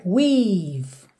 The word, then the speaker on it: we've: Aqui, muita atenção a essas letrinhas E que estão pintadas de cinza porque você realmente não pronuncia. Percebe que é we've. Você fecha o som no V.